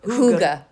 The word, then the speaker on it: hygge: Sort of, I always pronounce it Huggy, but anyway. – Huga. – Yes, well, it sounds like a hug. – It's spelled H-Y-G-G-E.